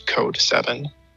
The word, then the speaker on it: C0D37: And I do all that under my producer name, which is Code 7.